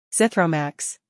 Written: Zithromax

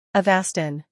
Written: Avastin

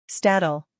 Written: Stadol

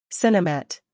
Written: Sinemet

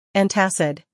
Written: Antacid

